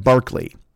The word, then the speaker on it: Berkeley: He spells his name George Berkeley, but you pronounce it like Barkley.